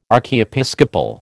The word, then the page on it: archiepiscopal: American English